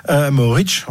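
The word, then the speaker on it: Mohorič: Mohoric, on va le prononcer à la Slovène.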